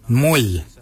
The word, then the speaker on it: mooly: Warrigal is spelled W-O-R-R-A-G-O-L. Warrigal. Warrigal is your upper mouth. For your nose, we say mwyl. Mwyl is your nose. Mwyl is spelled M-O-O-L-Y.